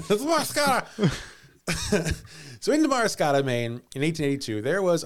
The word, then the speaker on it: Damariscotta: Damariscotta? – Damariscotta.